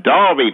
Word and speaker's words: Derby: By the way, guys, that's pronounced Derby. Just thought I'd, you know, add that. – Oh, yeah, sorry. Yeah, all of the 14 or so that are in America, you're quite right, are called Derby, and the four in Australia are called Derby, but the original one... – Uh-huh. – The one, the 2,000 years old one, is pronounced Derby, folks.